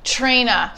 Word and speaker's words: Traina: Traina. – Traina. – I love it because everyone says it wrong. – Traina. – People want to say trainer or trainers or trainor and trains.